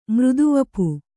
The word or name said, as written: ಮೃದುವಪು